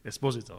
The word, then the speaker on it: Esposito: No, it's the correct pronunciation is Dino Esposito. – Esposito, okay.